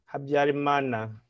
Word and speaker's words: Habyarimana: And Ramana, is that how you pronounce the name? – Habyarimana. – Okay.